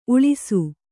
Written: ಉಲಿಸು

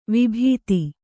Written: ವಿಭೂತಿ

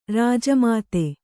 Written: ಋಜುಮತಿ

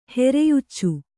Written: ಹೆರೆಯುಚ್ಚು

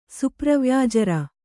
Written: ಸುಪ್ರವ್ಯಾಜರ

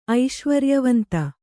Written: ಐಶ್ವರ್ಯವಂತ

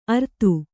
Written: ಅರ್ತು

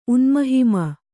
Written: ಉನ್ಮಹಿಮ

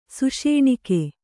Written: ಸುಷೇಣಿಕೆ

